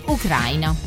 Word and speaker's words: Ucraina: Gli abitanti si chiamano ucrainei e quindi, come si vede, l'accento cade sempre sulla A. – Ucrainei? – In italiano diremo ucraini. Quindi Ucraina.